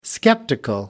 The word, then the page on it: skeptical: American English